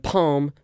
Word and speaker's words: palm: As you pronounce them, do the words farm and palm rhyme? So farm like chickens, you know, chicken farm. And palm, the palm of your hand. Yes, they do rhyme.